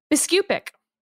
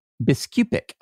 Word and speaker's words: Biskupic: Now, how do you pronounce Joan's name? – Biskupic. – Biskupic. Okay.